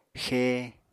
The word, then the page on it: xée: Apatani